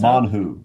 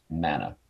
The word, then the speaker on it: manhu: For 40 years in the wilderness, so, manhu, or as it's usually pronounced, manna.